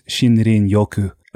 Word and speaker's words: Shinrin Yoku: What is Shinrin Yoku? Am I saying it correctly? And you're saying it beautifully.